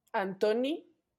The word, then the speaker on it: Antoni: Antoni Gaudí. Acento en la I. Y acento en la O. Antoni. – Antoni, ajá porque es catalán.